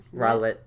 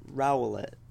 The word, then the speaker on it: Rowlet: Rowlet. – Rowlet.